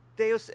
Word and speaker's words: Deus: It's Deus. It's short for like Deus Ex Machina, Ghost in the Machine.